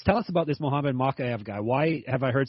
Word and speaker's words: Mokaev: So Dan, the fighter in question that John wants to know about, and of course Dan knows about it, is what, Mohamed Mokaev? Is that how you say it? Mokaev, yeah.